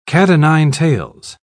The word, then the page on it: cat-o'-nine-tails: British English